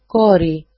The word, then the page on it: kori: ko-ri